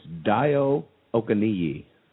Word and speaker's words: Dayo Okeniyi: It's Dayo Okeniyi.